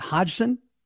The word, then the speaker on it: Hodgson: H-O-D-G-S-O-N. Is it Hodgson?